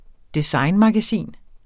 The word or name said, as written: designmagasin